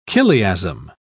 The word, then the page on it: chiliasm: British English